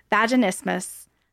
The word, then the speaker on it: vaginismus: So when Tara reached out and wanted to talk about her vaginismus, and I hope I pronounced it right, Tara. You can correct me. – Okay, good.